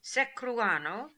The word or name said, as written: Seccruano